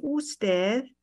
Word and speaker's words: usted: And how you say that is comprende usted.